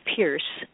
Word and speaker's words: Peirce: And my website is just my name, Penny Pierce, but it's spelled P-E-N-N-E-Y and then P-E-I-R-C-E, but it'll probably get there no matter how you spell it.